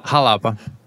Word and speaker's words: Xalapa: I don't know how to pronounce an X at the start of a word. Xalapa? – I think Xalapa? Xalapa? – Xalapa? It's like a Jewish X? – It's more like a ch sound, like a sh. Xalapa. – Yeah. Xalapa. – Xalapa. So it's called Xalapa. – Oh, no. It's actually phonetically pronounced on the side of the can there. – Oh, is it? – It's Xalapa. – Oh. – Xalapa. – Xalapa. – Like jalapeno. Xalapa.